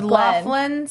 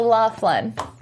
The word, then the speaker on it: Lachlan: It's Laughlin spelled like Lachlan. – Oh, yeah. So Laughlin.